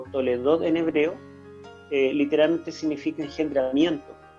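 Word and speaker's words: Toledot (תולדות): La palabra con que se encabezan todas las genealogías en el libro de Génesis y en otros libros del Antiguo Testamento es una palabra hebrea que se pronuncia Toledot, y se traduce al griego como Génesis.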